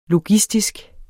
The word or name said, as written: logistisk